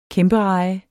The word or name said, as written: kæmpereje